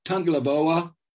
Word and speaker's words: Tagovailoa: Tagovailoa, the younger brother of Tua.